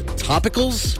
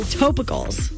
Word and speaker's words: Topicals: This fifth word, topicals. – Okay, that's how I have said it, but apparently we're both saying it wrong. The pronunciation is T-O-E, which to me is toe. – Toe? – Topicals. – Oh, I hate it. – Yeah. – T-O-P-I-C-A-L-S.